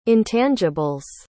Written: intangibles

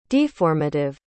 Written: deformative

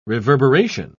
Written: reverberation

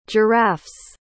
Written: giraffe's

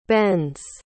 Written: bends

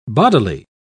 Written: bodily

